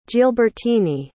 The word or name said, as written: gilbertine